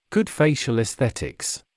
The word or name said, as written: good facial esthetics